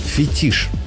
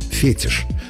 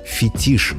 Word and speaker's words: фетиш: В этом слове ударение иногда ставят по ошибке. Переносят на первый слог – фетиш. Но литературная норма однозначна, и словари единодушны – фетиш с ударением на последнем слоге. Это объясняется происхождением. Слово пришло в русский язык из французского, где ударение обычно падает на последний слог. Русский вариант это ударение сохранил – фетиш.